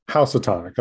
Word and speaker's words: Housatonic: Jubi is the recipient of the 2021 Housatonic Book Award. I may have mispronounced that. – Housatonic. – Okay, thank you. He's a 2018 PEN, that's P-E-N,